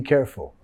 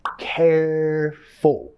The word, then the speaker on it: careful: Be careful. Nota la acentuación. Careful. Careful. Vamos a enfatizar la primera sílaba ahí.